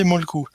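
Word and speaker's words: Mölkky: Moi, je connais le Molki, qu'on peut prononcer Molku.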